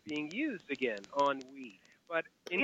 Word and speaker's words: ennui: I said, oh, my God, there's that word from 30 years ago, E-N-N-U-I. And if I... – Right. – I pronounce it on we. Am I wrong? – On we. Okay, on we.